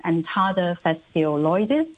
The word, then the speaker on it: Entada phaseoloides: And the official name is Entada phaseoloides. – Thank you. Your pronunciation is much better than mine.